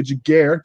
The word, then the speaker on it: Giguere: But uh, without further ado, we're going to let our guest, Miss Veronica Giguere.